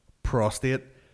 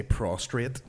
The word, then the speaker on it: prostate: Prostate. Why does everybody say prostrate? – Aye.